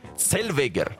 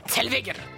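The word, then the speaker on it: Zellweger: Теперь давайте поговорим о гражданке Рене Зельвегер. У нее есть швейцарские и норвежские корни, и, кстати, ее фамилия, с учетом правил немецкого языка, произносится как Цельвегер. – Цельвегер.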